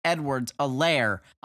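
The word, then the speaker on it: Edwards-Helaire: Clyde Edwards-Alaire. I'm told the H is silent by Al Michaels, who said that last night.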